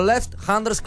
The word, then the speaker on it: left-handers: The Left Handers Club, ¿lo dije bien, Alejo? – Muy bien. – Left. ¿Y qué es eso? – El club de los zurdos. Yo le explico a los que no saben idiomas Anders. – Como yo. A ver, decilo vos, Alejo. – Ah, left-handers. Sí, lo pronunciaste perfecto.